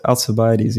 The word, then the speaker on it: Alcibiades: Alcibiades, the great Athenian general. Anyway. Yeah. So, was he in the Republic as well? – No. He has his own dialogue. – Saying that right. – Yeah, Alcibiades, yeah.